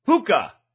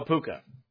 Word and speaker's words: Pwca: The Welsh called him PWCA, which is pronounced the same as his Irish carnation, Puka. Pookie Poo, no, Puka.